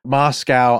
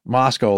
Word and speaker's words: Moscow: And then somebody kindly wrote in like, hey, you know, it's pronounced Moscow, like Costco.